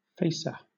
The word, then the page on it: facer: British English